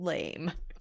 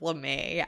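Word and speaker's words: lamé: And he goes, "I'm gonna be that guy, but instead of blue, it's gonna be gold lame." – Oh god. – Instead of LeMay.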